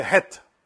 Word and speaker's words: h: That's why in English, the H is pronounced like a deep breath. Right? Hot. Hot. But in Spanish, the letter H is not pronounced, it's silent. But instead, we pronounce the het, which is the sound very strong. But that's the difference between the het and the hey.